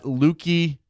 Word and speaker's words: Lukey: You can follow him on Twitter at Lukey Moore. There's a Y there. That's got to be pronounced that clearly. Lukey. Like his first name is Lukey Moore, right?